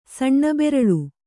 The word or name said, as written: ಸಣ್ಣಬೆರಲು